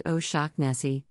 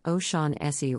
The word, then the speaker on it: O'Shaughnessy: Darren Shan's real name is Darren O'Shaughnessy, pronounced O'Shaughnessy, though it can also be pronounced O'Shaughnessy or O'Shaughnessy.